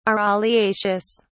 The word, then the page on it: araliaceous: British English